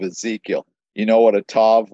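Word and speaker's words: tav (ת): The translation note in the NET says, the word translated mark in Hebrew is the letter tov. Okay. Well, it's tav is the pronunciation. The letter is called tav.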